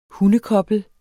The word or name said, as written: hundekoblet